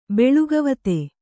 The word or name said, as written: ಬೆಳುಗವತೆ